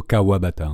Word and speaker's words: Kawabata: Alors sur le morceau qu'on va écouter, il y a Makoto Kawataba, j'espère que je prononce bien. – C'est Makoto Kawabata en fait.